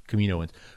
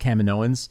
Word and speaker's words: Kaminoans: I hate that we've decided it's pronounced Kaminoans. I hate that because the planet's really? Called Kamino, and for years I was always like, no, it's Kaminoans because like they're from Kamino, and now it's Kaminoans, and I'm just like, ah, I hate that.